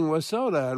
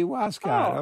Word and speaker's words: Wascott: Is it Mary in Wascott? – Oh, Wascott. Oh, all right. Well, I got an interesting spelling. – Mary, you know where Wascott is. I'm reading Wasota, and it should be Wascott.